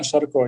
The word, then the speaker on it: Sharko: Bogdan Sharko.